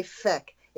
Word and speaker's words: feck: But the Irish, and actually some of the Australians, say feck. It rhymes with deck.